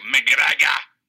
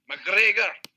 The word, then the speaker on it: McGregor: You have to say it like McGregor. – McGregor.